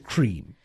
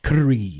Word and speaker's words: Kareem: So please don't call it cream. It's Kareem.